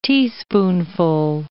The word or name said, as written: teaspoonful